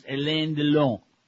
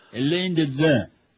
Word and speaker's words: Alain Delon: Alain Delon is pronounced Alain Delon. Yeah, it says, E is in French, is blah, blah, blah.